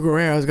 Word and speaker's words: Guerrero: Guerrero. – Those letters don't go together. Guerrero? What? – I think it's. – Say that 10 times fast. – I don't think you pronounce the U.